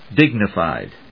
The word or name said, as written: dignified